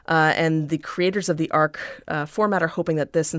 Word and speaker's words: Arch: It's spelled like arch, but it's pronounced like arc.